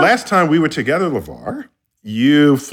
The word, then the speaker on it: LeVar: And by the way, Neil, I just want to say before we go any further, you're saying his name wrong. It is National Treasure, LeVar Burton.